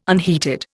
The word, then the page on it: unheated: Indian